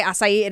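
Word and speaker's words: açaí: Is that how you say it? Acai, acai, it is acai.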